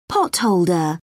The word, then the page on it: potholder: British English